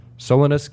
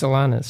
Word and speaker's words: Solanus: which is about Father Solanus Casey. Did I pronounce that, mispronounce that again? – Yeah, it's Solanus.